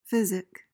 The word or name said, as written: physic